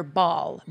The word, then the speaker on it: Baal: Baal. Baal. Baal is spelt B-A-A-L.